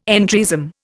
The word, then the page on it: entryism: Indian